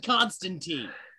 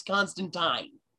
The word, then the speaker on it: Constantine: And I know you listeners out there are saying, why is he saying Constantine? Well, that's because that's how it's pronounced. – Uh-huh. I corrected myself in the beginning. – Not Constantine. Okay? It's Constantine. Fuckers.